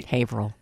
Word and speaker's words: Haverhill: I was born in Haverhill. – Haverhill.